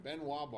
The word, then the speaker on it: Ben Wa: Quick interjection: Benoit balls, which are not spelled like Chris Benoit, by the way. – B-E-N-W-A.